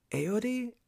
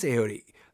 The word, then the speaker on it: Ayoade: Richard Ayoade? Ayoade? – I think it's Ayoade.